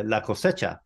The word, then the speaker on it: cosecha: So the next one was uh, I'm going to say this wrong. La cosecha is it La Coesha? – Uh, la cosecha. – That's la cosecha.